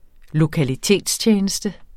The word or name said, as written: lokalitetstjeneste